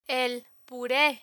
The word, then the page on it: el pure: Spanish